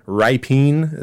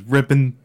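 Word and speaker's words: Rypien: Ripien is how it looks like it should be pronounced. Rippin.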